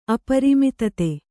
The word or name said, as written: ಅಪ್ರಮತ್ತತೆ